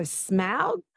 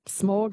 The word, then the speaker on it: Smaug: Apparently you say it like cow, so Smaug, but Smaug, Smaug.